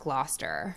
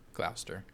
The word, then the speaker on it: Gloucester: And she was so heartbroken that she fell ill as she returned from London to her family home at Churchill in Gloucester. Did I fucking... – Worcestershire. That's what that sounded like. – I think it's pronounced Gloucester. – Gloucester. – Gloucester? – Gloucester, yeah.